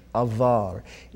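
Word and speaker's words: avar (עבר): It is spelled Ayin Beit Resh, avar.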